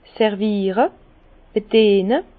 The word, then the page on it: serviere, bediene: Bas-Rhin; Herrlisheim